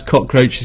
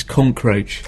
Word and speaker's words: cockroach: the fact you just pronounce cockroach is concroach.